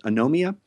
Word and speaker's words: Anomia: No, this is a game called Anomia, I believe it's called, is the way it's pronounced. Anomia. A-N-O-M-I-A.